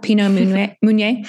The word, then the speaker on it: Pinot Meunier: And this week I chose, one of the wines I chose is called Pinot Meunier, and that's spelled M-E-U-N-I-E-R for those of you out there who are interested in checking it out. Pinot Meunier.